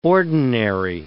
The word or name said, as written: ordinary